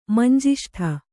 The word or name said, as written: ಮಂಜಿಷ್ಟೆ